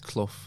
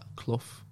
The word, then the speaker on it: Clough: It's Clough, like, but that's my accent. I think I don't know if Australians can pronounce it like that. Clough.